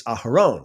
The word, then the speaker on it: Aharon: I mean, in the original Hebrew, it's Aharon, right? Aharon, that's how you say it.